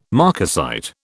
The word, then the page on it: marcasite: British English